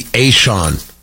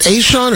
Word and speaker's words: ASEAN: Do they pronounce it Aishon? Or I don't think there's another way to pronounce that. A-S-E-A-N, the Association of Southeast Asian Nations. Yeah, I think that's the way it's gets pronounced.